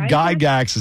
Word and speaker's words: Gygax: Is it, and I'm not sure the pronunciation, is it Gary Gygax? – Gary Gygax is the dead guy in the envelope.